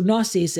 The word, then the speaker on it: gnosis: The G is silent. In Greek, we'd pronounce the G. We'd say gnosis. In English, they drop the G, it's silent. But in Greek, we would say gnosis.